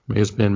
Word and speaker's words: Mazepin: And I might be pulling a curb here, and I might be totally mispronouncing his name, Mazepin. – Mazepin, Mazepin.